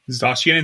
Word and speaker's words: Zacian: Zacian and Zamazenta. Did I say that right? Yeah. – Zacian and Zamazenta.